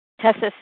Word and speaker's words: Tessa: Is it Tessa? T-E-S-S-A? – Tessa, star one to unmute. – Yes, it's Tessa.